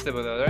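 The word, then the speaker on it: Thibodeaux: Yeah, how do you pronounce his last name? Kayvon Thibodeau? It is Thibodeau, right? Yeah, okay.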